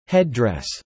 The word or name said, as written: headdress